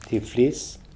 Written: Tiflis

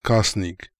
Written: Kasnik

